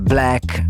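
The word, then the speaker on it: 6LACK: Si scrive Black, anzi no, si pronuncia Black. Al posto della B invece il 6, se vuoi scrivere il suo nome, anche se tendenzialmente capita poco di scrivere i nomi degli artisti, però...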